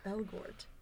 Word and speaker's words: Elgort: Elgort. – Gert. Elgort. Is that how you say it? – Ansel Elgort. – Elgort.